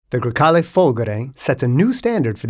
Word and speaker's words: Folgore: These characteristics of the car are expressed by the name Folgore, with the accent on the first O, which means thunderbolt, an incredible mix of power, electricity, and natural grace.